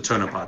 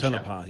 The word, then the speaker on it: Tonopah: That is right how you pronounce it, isn't that? Tonopah? – It's the Tonopah Test Range. – Tonopah, yeah.